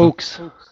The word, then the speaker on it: hoax: Comment vous prononcez ça? – Oaks. – OAuxs, ouais, c'est ça.